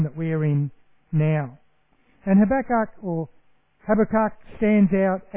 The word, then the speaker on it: Habakkuk: You can say Habakkuk a lot of different ways. People say Habakkuk, and Habakkuk is actually apparently how it's supposed to be pronounced.